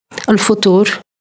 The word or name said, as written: elfotor